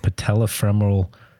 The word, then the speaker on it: patellofemoral: But the unique aspect of what Dr. Yankee does is a real expertise in what we call patellofemoral problems. You did a good job announcing it. You could barely say it when you said it. You said patellofemoral pain, or I think that's how what you said, right? – Patellofemoral.